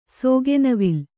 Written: ಸೋಗೆನವಿಲು

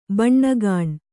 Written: ಬಣ್ಣಗಾಣ್

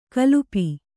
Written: ಕಲ್ಪಿ

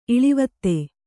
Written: ಇಳಿವತ್ತೆ

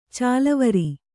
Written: ಚಾಲುವರೆ

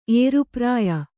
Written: ಏರುಪ್ರಾಯ

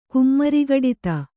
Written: ಕುಮ್ಮರಿಗಡಿತ

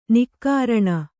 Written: ನಿಃಕರುಣಿ

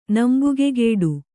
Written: ನಂಬುಗೆಗೆಡು

